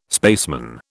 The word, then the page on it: spaceman: British English